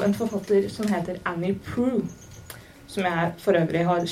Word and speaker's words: Proulx: Det skrives P-R-O-U-L-X. Så det er ikke, jeg syns ikke det var selvforklarende at det uttales pru, men det gjør det.